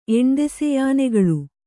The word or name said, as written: ಎಣ್ದೆಸೆಯಾನೆಗಳು